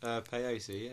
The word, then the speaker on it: Peyote: First of all, guys, am I pronouncing it right? – Peyote, yeah. – Yeah, you are, actually.